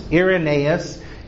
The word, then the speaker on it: Irenaeus: In his youth, an individual known as Irenaeus, I'll spell that for you, I-R-E-N-A-E-U-S, Irenaeus, who kind of eventually became the leader of the area of Lyons.